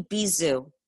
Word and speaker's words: Bisou: It's actually champagne Bisou. – Bisou. – Okay. Yeah, so it's like B and zoo. – Ah. – It's French. It means kiss.